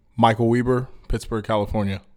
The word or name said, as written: Michael Weber